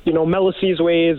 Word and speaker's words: Melisizwe: Yeah, our name, Melisizwe is, at the start of it all, Melisizwe was kind of a hard name for a lot of people to pronounce.